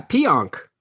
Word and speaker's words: Pionk: Yeah, Pionk. – Pionk. If I can drop Pionk for Shattenkirk, I'm going to do that. Because Shattenkirk is the favored guy right now. And if you're giving up Pionk, you're really not giving up a whole lot right now.